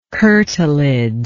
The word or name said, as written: curtilage